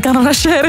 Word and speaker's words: canachère: Oh, ik kan dat niet uitspreken, hè. Carnachere? – Een canachère, ja.